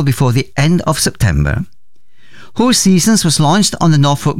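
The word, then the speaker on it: Hoseasons: Hoe Seasons, as it is pronounced,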